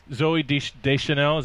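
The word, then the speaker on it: Zooey Deschanel: Zoe Deschanel, is that how you say it? – Yeah.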